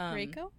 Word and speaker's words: Reiko: It was R-E-I-K-O. – Oh, Reiko? – Reiko.